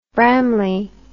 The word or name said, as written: bramley